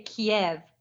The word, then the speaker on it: Kyiv: You could say Kiev, but that's the Russian pronunciation. Kyiv is the Ukrainian pronunciation.